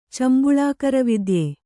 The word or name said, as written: ಚಂಬುಳಾಕರವಿದ್ಯೆ